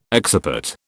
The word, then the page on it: occiput: British English